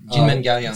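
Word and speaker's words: Kinmen Kaoliang: Jinmen Galleon. – It's not pronounced the way it's spelled with the K's.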